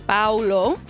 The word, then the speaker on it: Paolo: Paolo Banchero. – Paolo Banchero. – Mm-hmm. It's an A and O right next to each other, the first syllable.